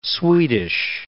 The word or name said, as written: Swedish